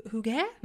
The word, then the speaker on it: hygge: Okej, okay, hygge Wymawia się hygge, mhm. – tak? Hygge.